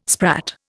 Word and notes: sprat: Indian

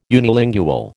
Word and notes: unilingual: American English

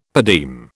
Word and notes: per diem: British English

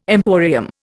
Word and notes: emporium: Indian

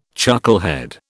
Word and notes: chucklehead: British English

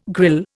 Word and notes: grille: Indian

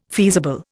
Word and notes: feasible: Indian

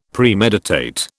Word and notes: Premeditate: British English

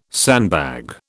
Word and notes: sandbag: British English

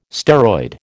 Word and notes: steroid: American English

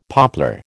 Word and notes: poplar: American English

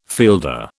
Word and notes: fielder: British English

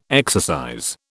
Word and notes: exercise: British English

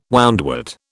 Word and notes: woundwort: British English

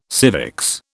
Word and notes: civics: British English

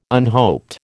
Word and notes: unhoped: American English

